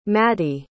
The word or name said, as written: Maddy